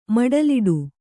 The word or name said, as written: ಮಡಲಿಡು